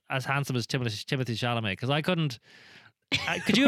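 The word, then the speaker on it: Timothée Chalamet: Timothy Chalamet hosted SNL the other day.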